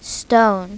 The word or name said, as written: stone